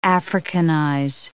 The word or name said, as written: Africanize